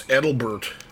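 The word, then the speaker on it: Adelbert: It's not Albert, it's Edelbert.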